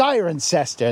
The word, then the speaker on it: Cirencester: It's pronounced Cirencester.